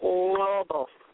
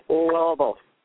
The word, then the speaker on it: Lobo: My last name is pronounced Lobo. There's a click sound in there, Lobo.